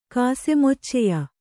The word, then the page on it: ಕಾಸೆಮೊಚ್ಚೆಯ: kannada